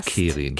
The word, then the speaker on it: Kerin: Es ist ein friesischer Name. Ähm, da wird er allerdings meist mit Doppel-R geschrieben und dann wird er Kerin ausgesprochen.